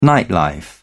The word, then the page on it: nightlife: British English